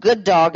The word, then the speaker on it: Guddawg: And so this man has created, it's called Good Dog, and it's spelled G-U-D-D-A-W-G.